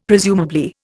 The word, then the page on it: presumably: Indian